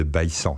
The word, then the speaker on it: Baïssan: il faut dire c'est le domaine de Baïssan, c'est comme ça que ça se prononce localement.